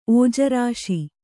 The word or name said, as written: ಒಜ್ಜರಿಸು